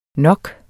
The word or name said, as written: nok